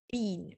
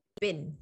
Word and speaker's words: been: So I say been, right? The Americans say bin. Sounds like bin, you know, trash can, trash the bin. But if I want, I say been. Amna says bin, right, Amna?